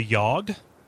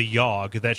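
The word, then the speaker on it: Yawhg: a game called The Yogg.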